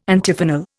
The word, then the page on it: Antiphonal: Indian